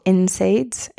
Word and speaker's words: NSAIDs: So we're thinking aspirin or non-steroidal anti-inflammatory drugs or NSAIDs, that's how people pronounce it, such as ibuprofen and naproxen that can lead to GI tract bleeding.